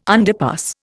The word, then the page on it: underpass: Indian